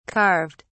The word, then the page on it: carved: British English